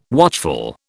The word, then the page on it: watchful: British English